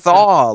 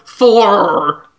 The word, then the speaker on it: Thor: And also 2011, now we've all heard of Thor, right? – What? – Thor. – Oh, Thor. – Comic book licensed Marvel. – I thought you said Thaw, like thawing. – Yeah. No, Thor.